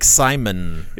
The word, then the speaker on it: Szymon: S-Z-Y-M-O-N. Szyzmon? – Oh, it's like Simon.